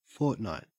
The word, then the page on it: fortnight: Australian English